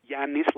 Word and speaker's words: Giannis: Giannis, like with a Y sound, first of all, for his first name.